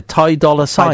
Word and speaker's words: Ty Dolla Sign: But they didn't write out that that's how you pronounce the name. They put a dollar sign.